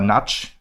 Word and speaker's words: Nagy: Aber ja, das 1-0, Virag Nagy oder Natsch, wie sie eigentlich ausgesprochen wird,